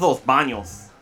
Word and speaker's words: Los Banos: You gotta say it right, man. It's Los Banos.